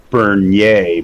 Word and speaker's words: Bernier: Just like my name is Bernier, but everybody just pronounces it Bernier, and I stopped fighting that when I was like eight.